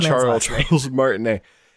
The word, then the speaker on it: Charles Martinet: Charles Martinet.